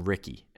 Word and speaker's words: Ricci: For example, there's someone with the last name Ricky, and it's spelled R-I-C-C-I, but they would spell it R-I-C-K-Y.